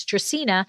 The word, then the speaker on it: Dracaena: And that's so funny that you bring up Game of Thrones because people have such a difficult time saying Dracaena. A lot of them say Dracaena or whatever. And it's Latin, it's Dracaena.